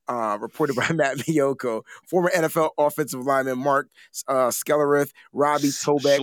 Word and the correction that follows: Schlereth: Schlereth, whatever, Adam Timmerman, Jerry Fontenot.